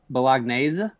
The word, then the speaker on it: Bolognese: The Joey Bologna snack. Is that the correct pronunciation? – It's Bolognese.